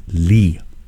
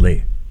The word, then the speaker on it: Leigh: L-E-I-G-H is Lee. – Ooh. – It's not Lay. Although it sounds like a Lay, it is pronounced as a Lee.